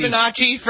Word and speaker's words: Fibonacci: Fibonacci.